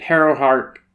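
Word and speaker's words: Harrowhark: One day, as Gideon is trying to escape, Harrow Hark...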